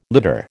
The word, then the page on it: litter: American English